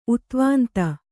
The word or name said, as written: ಉದ್ವಾಂತ